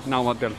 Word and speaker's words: Náhuatl: Náhuatl. náhuatl. Se pronuncia la T y la L.